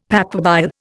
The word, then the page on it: papabile: Indian